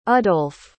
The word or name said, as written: udolf